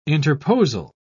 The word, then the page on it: interposal: British English